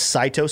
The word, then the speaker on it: Saito: We got one from Monica T saying, okay, so this coin is pronounced Saito. So S-A-I-T-O, Saito.